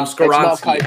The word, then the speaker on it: Skoronski: I'm probably mispronouncing that. Skorinsky. Um, Skoronski.